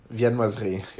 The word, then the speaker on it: viennoiserie: I also wanted to recommend uh, sourdough panettone and uh, viennoiserie. How do you pronounce that accurately? – Viennoiserie. You were pretty good.